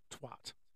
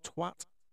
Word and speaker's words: Twatt: Believe it or not, there is a place in Orkney called Twatt. Now, it's spelt T-W-A-double-T, so it's not quite the same as the beloved swear word, but it's close enough, I think.